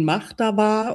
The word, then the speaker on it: Mahtava: Du nennst dich auf Instagram und auch auf deinem Blog, und jetzt spreche ich es bestimmt falsch aus, Matova? – Also es wird ausgesprochen Mahtava und es ist tatsächlich so ein finnisches Wort.